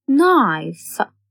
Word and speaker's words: knife: K is a silent letter in knife, knee.